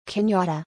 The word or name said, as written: Kenyatta